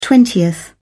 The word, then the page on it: twentieth: British English